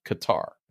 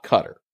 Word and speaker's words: Qatar: You know, pronunciation of Qatar, Qatar, Qatar, which I double checked that the government in Qatar actually says you should pronounce it Qatar, not Qatar.